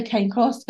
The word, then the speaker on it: Cane Corso: saying that wrong. I've been saying that wrong. – Sounds good. – I would say cane corso, but cane corso, not cane Yeah. – corso.